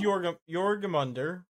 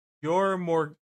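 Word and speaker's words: Jörmungandr: So, this is Jorgamunder. So you say it. It's from Norse mythology. It means, quite literally, huge monster. And it's evidently pronounced what the hell? Jormungandr.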